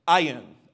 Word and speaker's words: ayin: The Hebrew word for eyes is ayin. Ayin, I don't really know how to say it. It's A-Y-I-N.